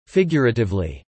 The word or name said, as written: Figuratively